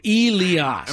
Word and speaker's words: Elias: It's E-E, which is obviously E. And then the next part is capital, L-E-E, Lee, and then A-H-S, Oz. So Elias.